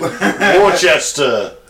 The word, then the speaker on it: Worcester: It's Worcester sauce is what everyone confuses, isn't it? Worcester! – Worcester! – Worcester! Is that how you say it? – Yep.